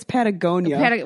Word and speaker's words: Patagonia: Actually, it's Patagonia.